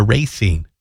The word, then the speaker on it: Racine: Technically, Martell from the Racine area, or if you're from there, Racine is how it's pronounced.